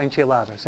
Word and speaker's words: enchiladas: No, la novela, comer tacos, enchifladas, todo esto, ¿no? Oh, enchiladas, siempre lo digo. Siempre lo digo mal.